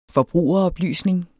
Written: forbrugeroplysning

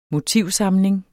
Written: motivsamling

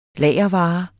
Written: lagervare

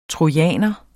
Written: trojaner

